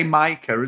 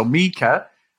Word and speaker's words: Mika: well, Dave, we will start with Brett and Mika, Dave, as I've been told I was saying it wrong, but in the UK we do say Micah as well, don't we? So Mika, uh, they got together, Dave, and then that was basically the end of their relationship on screen.